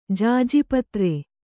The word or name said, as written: ಜಾಜಿಪತ್ರೆ